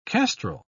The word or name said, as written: kestrel